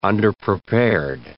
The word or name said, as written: underprepared